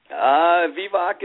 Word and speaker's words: Vivek: Vivek is how it's pronounced, and we're located in northern New Mexico, a little town called Dixon.